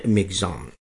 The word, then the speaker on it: Michaung: अंग्रेजी में जो इसकी स्पेलिंग है वो है MICHAUNG, लेकिन आकाशवाणी का उच्चारण है मिगजाम।